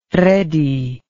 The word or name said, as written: Redi